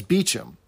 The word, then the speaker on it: Beauchamp: Because we figure out that his name is probably spelled B-E-A-U-C-H-A-M-P. – Right. – So you'd think that it would be pronounced Beauchamp or Beauchamp if it's in the French ways, but the character corrects him as Beecham.